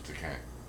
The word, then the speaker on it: Takei: Excuse me, Takei. Rhymes with gay.